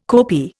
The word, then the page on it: kopi: Indian